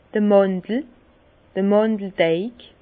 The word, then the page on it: d'r Màndel, Màndeldeig: Bas Rhin; Herrlisheim